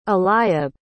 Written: eliab

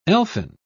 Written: elfin

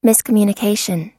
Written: miscommunication

